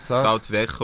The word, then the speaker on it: Weghorst: And that man, in my opinion, should be 26-year-old Dutch striker Wout Voghest. – Oh, yeah. – Do you know, am I pronounced his name right? As resident Dutchman? – It'd be Fout, I think. – Fout Weghorst.